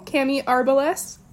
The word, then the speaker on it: Cami Arbeles: I'm your host for season two, and I'm here with the wonderful and incomparable Cami Arbeles. Did I pronounce that right? – Yes, you did. Okay. Accent on the A.